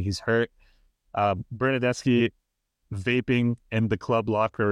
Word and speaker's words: Bernardeschi: So much so that they brought his buddy Bernardeschi. Am I saying that right? – Yeah, Bernardeschi.